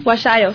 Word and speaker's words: Huasayo: Así es que se pronuncia, ¿verdad? Sí, ¿no? – Huasayo, sí. Huasayo. Una palabra quichua.